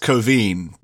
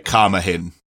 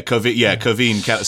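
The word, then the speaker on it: Caoimhín: Yeah, it's pronounced Coveen, but when you see it written down, it looks like Carmahin. It's one of those great... – Quivian Kelleher. – Yeah, Coveen.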